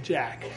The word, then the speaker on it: Jacques: It's actually spelled like the French way, Jacques. But we're in America, so we say Jack.